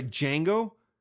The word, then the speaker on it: Django: I'm sorry, Django. D is silent.